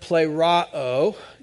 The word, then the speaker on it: pleroo: If you don't know, it's the Greek word pleroo. It says, you say it like this, if you wanted to know. Now you know.